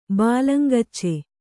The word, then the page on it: ಬಾಲಂಗೋಚಿ: bālangōci